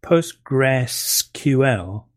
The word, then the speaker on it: PostgreSQL: postgresql but it, it's still pronounced postgres by most people